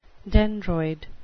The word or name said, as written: dendroid